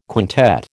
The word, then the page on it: quintet: American English